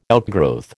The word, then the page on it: outgrowth: American English